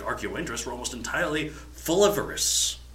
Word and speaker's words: folivorous: If I can pronounce it, folivorous. – Folivores, yeah, folivorous. – Folivorous. Okay.